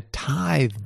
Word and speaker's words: Tythe: It's called the Tithe Barn. Now, they spell that T-Y-T-H-E. – Oh, like the tithe of to the church or something.